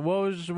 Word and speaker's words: Woj: So Woj, where's your house key? – Yeah, that's it's how I've been instructed to pronounce it.